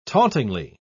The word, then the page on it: tauntingly: British English